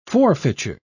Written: forfeiture

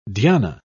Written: dhyana

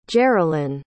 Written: jerelyn

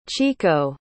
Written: chiko

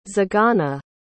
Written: zigana

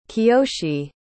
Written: Kiyoshi

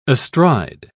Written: astride

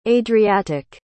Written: adriatic

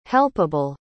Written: helpable

